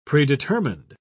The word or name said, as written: predetermined